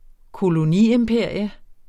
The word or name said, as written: koloniimperium